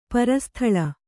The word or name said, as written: ಪರಸ್ಥಳ